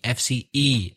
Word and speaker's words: FCE: FCE exam. You actually said FCA. Be careful. ABCDE, FCE exam.